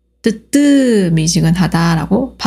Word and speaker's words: 뜨뜻미지근하다: 그리고 대부분 뜨뜻이 아니라 뜨뜨 미지근하다라고 발음합니다.